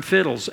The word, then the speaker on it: FHIDLS: It contains, if you've been around here very long, you've heard the term fiddles, F-H-I-D-L-S. Sounds like fiddles, but it's spelled F-H-I-D-L-S.